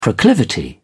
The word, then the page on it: proclivity: British English